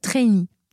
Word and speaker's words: trainee: D'ailleurs, quand on est étudiant, en K-pop, quoi, je sais pas comment on dit, mais on dit qu'on est trainee. Et le I, il est important, voilà, la façon de le prononcer est importante, parce que ça s'écrit T-R-A-I-N-E-E. Voilà.